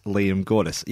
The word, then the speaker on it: Liam Gordes: You can find me on Twitter and Instagram at Liam Gordes.